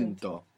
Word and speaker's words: intento: Intentar, we have the accent on the end. When we put the O, the accent is just before it. Intento. Now, this is extremely important. If I say intento, if I put the accent on the end, it means something else.